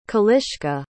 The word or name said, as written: kaliska